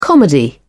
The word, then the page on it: comedy: British English